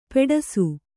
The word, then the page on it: ಪೆಡಸು: kannada